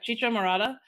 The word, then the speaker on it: Chicha morada: I think it was like Cheetah Morada. It's made from purple corn. Yeah, Chicha Morada.